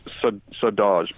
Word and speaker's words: Saudade: I'm pretty sure you say Saudade, but you know how it's spelled, right? – Yes, I do. Yeah. – It's S-A-U.